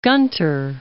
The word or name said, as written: Gunter